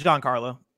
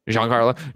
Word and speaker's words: Giancarlo: And I do think that uh, Giancarlo, right? And I'm saying that wrong, aren't I? It's, it's Giancarlo. – Giancarlo. Giancarlo yeah.